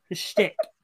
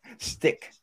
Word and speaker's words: shtick: Peace isn't my schnick. – Shtick. – Shtick. – Pronounce the T.